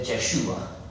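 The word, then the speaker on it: Yeshua (ישוע): And when I got to Jesus, I said, Yeshua. But that's right. That's how it's pronounced.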